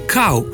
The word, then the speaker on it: kal: Odgovor na vprašanje deloma ponujajo priročniki, in sicer slovenski pravopis, ki pravi: kav v pomenu mlaka bi dejansko brali kav, medtem ko sta v pomenu zelena kal, kal krompirja, klica in podobno možni obe obliki izgovorjave, kal in kav.